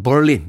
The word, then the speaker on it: Berlin: 베를린, 네, 발음은 Berlin 이렇게 하더군요.